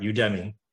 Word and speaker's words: Udemy: You said it right, Udemy.